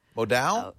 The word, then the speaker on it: modal: You've probably heard of modal? Modal? – Modal is the way I pronounce it.